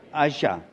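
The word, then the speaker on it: Ağca: His name was Akra, right? Is that? Asha, pronounced Asha, right.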